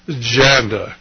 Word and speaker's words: Janda: Now, over the weekend, I was up on the Internet, and I read an article about a Dr. Janda, it rhymes with panda, only it's spelled with a J, who is an orthopedic surgeon, and so he's written some articles that are up on the Internet.